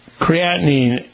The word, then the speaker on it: creatinine: The creatine level in your system, what the numbers mean? – Creatinine. A measure in the blood of a way that we assess the kidney function.